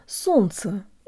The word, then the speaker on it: солнце: guys, and today you'll learn how to pronounce the word солнце, or sun, as in the sun in the sky. In this word, the L and the N blend together, which makes things a lot simpler.